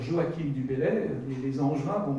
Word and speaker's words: Joachim: Je fais exprès de dire Joachim du Bellay parce que souvent on prononce mal. C'est un français, hein, les CH se prononcent CH.